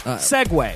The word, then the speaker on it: segue: Is S-E-G-U-E. And a segway you ride on is S-E-G-W-A-Y. – Well, why do you gotta... It's pronounced the same.